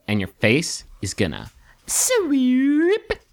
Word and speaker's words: Swewp: S-W-E-W-P. It's pretty much phonetically. – So it's pronounced sweep.